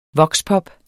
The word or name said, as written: VOXPOP